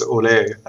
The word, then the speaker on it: Ole: And my name is Ole. Actually, it's more like the Spanish pronunciation.